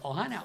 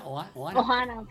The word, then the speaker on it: Ohana: ¿Cómo se dice? Ohana. – Ohana, Finding Ohana.